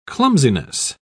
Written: clumsiness

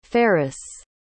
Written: ferris

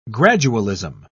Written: gradualism

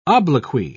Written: obloquy

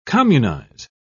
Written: communize

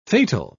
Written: fatal

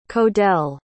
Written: codell

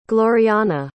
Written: glorianna

